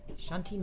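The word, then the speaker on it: Chanti: Shanti Niven, N-I-V-E-N. And the first name is spelled with a C, by the way. It's not S. It's C-H-A-N-T-I.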